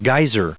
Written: geyser

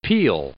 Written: peel

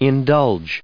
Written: indulge

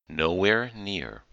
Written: nowhere near

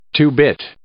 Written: two-bit

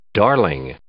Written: darling

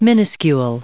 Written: minuscule

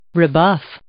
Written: rebuff

